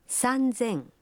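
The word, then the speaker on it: Санзен (さんぜん): Но и здесь произношение меняется. 3000 будет звучать как? – Санзен.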